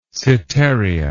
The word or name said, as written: setaria